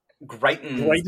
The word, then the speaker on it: Greitens: Greitens.